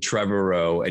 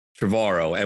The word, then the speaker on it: Trevorrow: And I love saying his name because Colin, when we met you at Fallen Kingdom in Hawaii, I think someone said Trevorrow and you said, no, it's like tomorrow, Trevorrow.